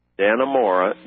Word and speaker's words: Dannemora: and you spell that D-A-N-N-E-M-O-R-A, Dannemora, just like it sounds, D-A-N-N-E. M-O-R-A, one word, Dannemora, comma, New York, 12929.